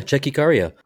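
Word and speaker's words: Tchéky Karyo: All right, I'm going to butcher this dude's name, but Kiss the Dragon's the one with Checky Caro, isn't it? – Yeah, Checky Caro.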